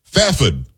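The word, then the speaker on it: Fairford: Fafod is what I was told how I should pronounce it from K.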